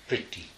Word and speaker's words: Priti: Sanskrit equivalent, I'm not sure exactly how it's pronounced, but it's spelled with an R. P, R with a dot over it, T-I. Priti, I think, is how it's said. Yeah, like priti. Like the roll of your tongue a little bit.